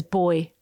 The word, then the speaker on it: buoy: There was one called Leaf Peeper, Buoy Band, which is only really funny to people like me, aka Australians, who pronounce buoy as boy. This is boy band.